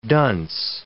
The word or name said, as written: dunce